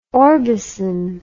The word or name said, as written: orbison